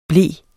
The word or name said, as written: ble